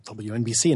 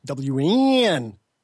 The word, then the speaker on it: WNBC: how do you say the call letters? And Howard Stern said, well, WNBC. And the guy goes, no, it's WNBC. You got to do that thing with the N.